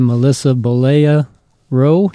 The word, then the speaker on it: Bollea Rowe: We have Melissa Bollea Rowe.